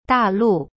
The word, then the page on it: 大陆: da lu